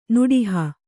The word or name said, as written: ನಡಹು